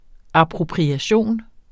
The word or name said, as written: appropriation